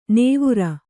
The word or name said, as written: ನೇವುರ